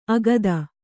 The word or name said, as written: ಆಗತೆ